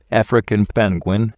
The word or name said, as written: African penguin